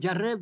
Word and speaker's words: Jarre: Bueno, aprovecho también, después de hacer este comentario sobre la numerología, sobre Michel Jarre, que se escribe Jarre, pero se pronuncia Jarre porque la E en francés no se pronuncia.